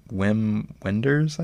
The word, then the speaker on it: Wim Wenders: But no, it's my first, how do you say his name? Wim Wenders, I guess? – Yeah.